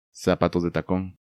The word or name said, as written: Zapatos de tacón